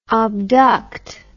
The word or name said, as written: obduct